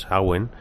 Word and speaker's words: Samhain: Sí, vamos a ver, tenemos ahí los celtas, ellos instauran la fecha Samhain, o como dicen los gaélicos, Samhain, que es la pronunciación que he aprendido precisamente con esta ponencia,